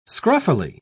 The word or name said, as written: scruffily